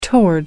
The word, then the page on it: toward: British English